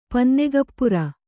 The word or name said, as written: ಪನ್ನೆಗಪ್ಪುರ